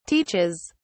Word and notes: teaches: British English

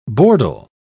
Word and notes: bordel: British English

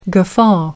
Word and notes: guffaw: American English

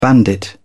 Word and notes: bandit: British English

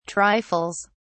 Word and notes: trifles: British English

